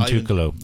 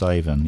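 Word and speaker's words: Iwan Tukalo: Ivan Tukalo. Ivan Tukalo. Iwan, but pronounced Ivan.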